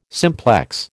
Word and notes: simplex: American English

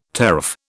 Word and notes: teraph: British English

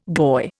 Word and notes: goy: Indian

